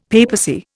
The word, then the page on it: papacy: Indian